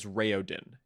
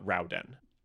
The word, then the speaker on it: Raoden: And I mentioned before that, generally speaking, I ignore a lot of the official pronunciations of Elantrian terms, because the rule is that Aeonic names, all of the vowels are pronounced separately. And this is basically the only one that I actually follow that on. His name is Raoden and not Rauden.